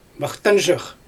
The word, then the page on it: maxtanzhyk: Xyzyl